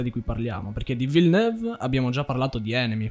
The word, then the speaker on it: Villeneuve: aspetta, come l'hai pronunciato? – Villeneuve. – Si pronuncia così?